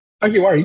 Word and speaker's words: Hagiwara: Hagiwara, you got it perfectly.